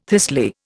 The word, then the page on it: thistly: Indian